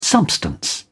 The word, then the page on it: substance: British English